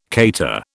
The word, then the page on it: cater: British English